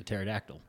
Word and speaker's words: pterodactyl: Pterodactyls are spelled with a P. Oh, my God. The P is silent.